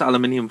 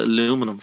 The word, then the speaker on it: aluminium: Pronounce aluminium for me. – Aluminium. – Yeah, but you guys call it aluminum for some reason.